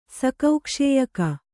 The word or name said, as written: ಸಕೌಕ್ಷೇಯಕ